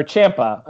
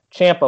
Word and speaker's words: Ciampa: But yeah, we've said that we think Dijakovic will be, and then you've said you think Ciampa may also be, or Ciampa, I'm sorry, I hate that I mispronounce his name like that. Ciampa will also be potentially a part of it.